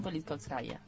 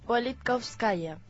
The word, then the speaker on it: Politkovskaya: Ana Politkovskaya. – ¿Y se dirá así? – No sé. – Sí, se dice así. – Politkovskaya.